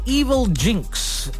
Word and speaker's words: Evilginx: It's called Evil Jinx. Yeah, it's spelled E-V-I-L-G-I-N-X.